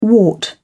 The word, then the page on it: wart: British English